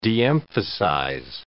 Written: de-emphasize

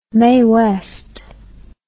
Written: mae west

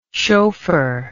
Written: shophar

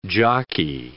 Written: jockey

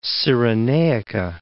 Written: Cyrenaica

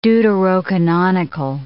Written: deuterocanonical

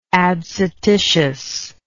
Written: adscititiously

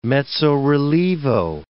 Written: mezzo-relievo

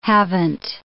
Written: haven't

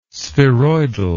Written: spheroidal

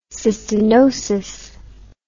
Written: cystinotic

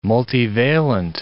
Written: multivalent

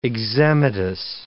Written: eczematous

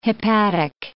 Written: hepatic

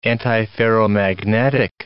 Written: antiferromagnetic

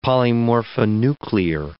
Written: polymorphonuclear

